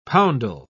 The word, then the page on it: poundal: British English